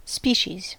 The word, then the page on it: laji: [ˈlɑji]